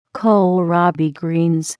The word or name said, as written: kohlrabi greens